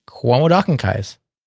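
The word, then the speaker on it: quomodocunquize: Cuomo Dockenkise. That's one way to pronounce it. There are a few other pronunciations, but I won't try them.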